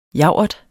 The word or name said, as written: javert